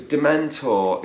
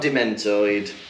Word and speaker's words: Dementor: I don't know why he does that, because Dementor is not a hard word to hear. – No. Just say it properly, right? It's not Dementoid.